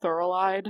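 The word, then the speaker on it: Thorilide: She's like, and Palpatine is the ruler, and that's fine, and we need all this, what are we talking about, Thorolide? Is that how you say it? – Correct.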